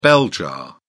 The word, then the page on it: bell jar: British English